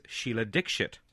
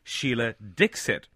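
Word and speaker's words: Sheila Dikshit: Her name is Sheila Dixit. That is actually the pronunciation of her name. But Television New Zealand, where he was working at the time, were pronouncing it Sheila Dixit.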